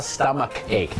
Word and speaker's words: stomach ache: stomach ache ch อาจจะเป็นชอช้างแต่ ch ก็เป็นคอควายก็ได้นะครับ